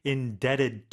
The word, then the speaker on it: indebted: truly indebted to you. And that be is silent, everyone, right? – 맞아요.